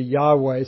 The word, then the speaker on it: Yahweh: And so it's almost impossible to pronounce. Closest in English would be Yahweh.